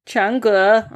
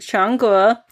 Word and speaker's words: Chang'e: Chang'e. Let's just see whether I said it right. Play. Chang'e. Oh, I said it wrong for fuck's sake. Chang'e, which basically retells the classic Chinese myth of the woman on the moon.